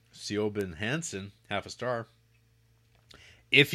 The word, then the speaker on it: Siobhan: I believe this person's name is pronounced Siobhan. – Oh. – And the only reason I know that, Jared, is because of Succession. Because there's a character named Siobhan, and it's spelt like this.